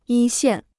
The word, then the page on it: 一线: yī xiàn